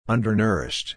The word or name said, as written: undernourished